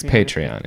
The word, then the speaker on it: Patreon: If you say Patreon, it's Patreon.